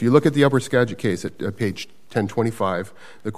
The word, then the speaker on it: Skagit: Upper Skagit, yes, the Upper Skagit case.